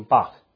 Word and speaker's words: Bach: and that man's name was Johann Sebastian Bach. I have to say Bach like that because my college music teacher said we have to say it like that, so pardon me.